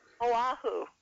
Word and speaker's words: Oahu: You tell them though, Central Oahu. – Okay. – Well, how do you pronounce that? – Oahu.